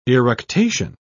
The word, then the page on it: eructation: British English